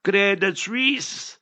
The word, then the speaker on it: Credit Suisse: You must know how to pronounce it also. Credit Suisse.